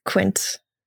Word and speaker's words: Quint: I always thought I would pronounce cunt. Is Quint? – It's Quint.